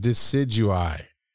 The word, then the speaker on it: Decidueye: How do you say that? Decidueye. Because it's E-Y-E. So it's got to be Decidueye.